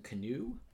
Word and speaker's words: Kanew: Blossing and David Sheffield, so the writers of the original movie, and Justin Canoe? I don't know. It's not spelled like canoe, like the boat. – Okay. – It's like K-A-N-E-W.